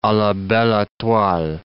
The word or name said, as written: a la belle etoile